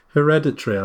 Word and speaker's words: hereditary: So June the 15th is Hereditary or Hereditary or how do you pronounce it? – Hereditary, I would say.